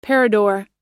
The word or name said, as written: parador